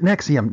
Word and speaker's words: NXIVM: an organization called Nexium, only they really, really spell it wrong. I'm glad there was a pronouncer for this in the news article, because they spell it NXIVM. Pronounce it Nexium.